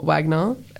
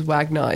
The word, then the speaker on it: Wagner: He pronounces his own name as Wagner, even though every time I mention his name, my mum's like, it's actually Wagner. I'm like, Mum, I know. But he just chooses to mispronounce it.